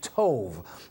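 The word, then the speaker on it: tov (טוב): And that word good is actually tov, T-O-W-B. It's pronounced tov, which means for your benefit.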